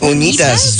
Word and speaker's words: Unitas: Unitas? – How do you say that last name? Unitas? – Tengo unas unitas. – Unitas.